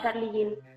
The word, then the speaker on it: Charly Gynn: Sí, sí, justo, se pronuncia así tal cual, Charlie Jean.